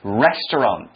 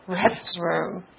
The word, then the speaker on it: restaurant: spelled R-E-S-T-A-U-R-A-N-T, restaurant. – OK, 那提到这个字的时候呢，大家也要注意它的发音，因为第一个字我们要学到的就是呢，今天我们的主题啊，我们要去外面去餐厅吃饭，餐厅的英文就是 restaurant. Now, a lot of people here in Taiwan actually pronounce this word as restroom. – Oh, restroom. – Yeah, it sounds kind of like restroom.